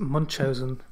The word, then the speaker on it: Munchausen: Well, I think the correct pronunciation is Munchausen.